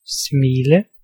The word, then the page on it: laugh: /læf/